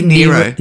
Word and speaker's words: Nyro: Now you pronounce it Nyro. I pronounce it Nero. Is there. Do you know if there is a correct pronunciation of this? – There is, and I can't remember which one it is. I think it might be Nero.